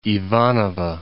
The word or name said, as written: Ivanovo